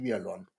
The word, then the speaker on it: Vialon: Der Song heißt Eine Hölle in Pastell und das Feature ist von Amy, wie wird die ausgesprochen? Amy Vialon? Amy? – Ja. – Amy Vialon. Ja, das ist, ja, ist das Innenansicht, ist das schon fast wieder politisch?